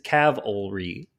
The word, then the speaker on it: cavalry: Okay, so the correct pronunciation is cavalry. – Huh. – You're putting the L before the V. – It's not Jesus died on Calvary.